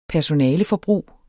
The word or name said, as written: personaleforbrug